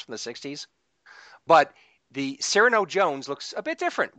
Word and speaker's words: Cyrano: But I did like seeing Cirio Jones. Cirio Jones. How do you say his name? – Cyrano. – Cyrano Jones. – Like Cyrano de Bergerac.